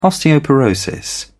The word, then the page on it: osteoporosis: British English